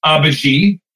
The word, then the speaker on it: Abagy: The company is pronounced Abagy, A-B-A-G-Y.